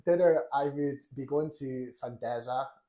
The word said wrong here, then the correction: Fazenda: Fandesa, but my girlfriend has corrected me saying it's Fazenda.